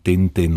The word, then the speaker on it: Tintin: Vytvořil řadu postav, ale suverénně nejznámějším je určitě statečný reportér Tintin, nebo jak se často vyslovuje u nás Tintin. S omluvou všem franštinářům se pro případ dnešních příběhů z kalendáře přichýlíme k té české verzi.